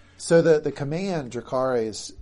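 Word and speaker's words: Jacaerys: Jacarys. – Seems like Jacarys, on the other hand, was really down with that goat getting burned.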